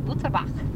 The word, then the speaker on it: Butterbaugh: Butterbaugh. – Which is pronounced? – Well, if you're in German, it's Butterbach. – Butterbach. – But it's Butterbaugh if you're in California.